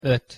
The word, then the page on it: ot: Hungarian